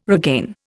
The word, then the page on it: regain: Indian